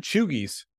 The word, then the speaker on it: cheugy: So we're cheugies. – How do you spell that? – C-H-E-U-G-Y, pronounced cheugie.